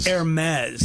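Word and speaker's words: Hermès: More mez than maze. – Oh, it is. Hermes. – Yeah. – But I've always said Hermes. – We all do. – Maze. – Right? – Yeah, no matter what part of the word you focus on, then you mess up the other part. – Yeah. – Yes. Like, you know, it's the silent, the H is silent.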